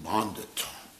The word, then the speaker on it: pandit: There was this conquering pandit. The Prabhupada said, bandit. A bandit. Mm-hmm. Prabhupada pronounced it bondit. That was Prabhupada's pronunciation. Pondit, bondit. That's how Prabhupada would pronounce things.